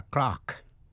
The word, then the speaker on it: clerk: Thế còn cách phát âm của chữ clerk, miền Nam nước Anh, trong tiếng Anh chuẩn received pronunciation, chữ clerk đọc là clerk. Trong tiếng Mỹ đọc là clerk với vần e. Tiếng Anh đọc là clerk với vần r. Bây giờ ở Mỹ chỉ dùng chữ clerk, C-L-A-R-K, để chỉ tên người mà thôi.